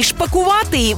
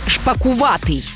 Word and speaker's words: шпакуватий: Наголос на другому складі – шпакуватий. Бо якщо скажеш «шпакувати», звучатиме дивно, як новий вид птаха.